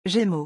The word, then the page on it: gemeaux: French